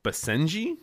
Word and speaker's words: Basenji: Basenji? – Nice.